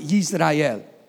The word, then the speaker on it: Yisrael: In Hebrew, it's you pronounce it Yisrael with a Y.